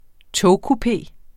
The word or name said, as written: togkupé